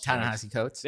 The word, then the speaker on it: Ta-Nehisi Coates: Ta-Nehisi Coates, who also Ta-Nehisi Coates.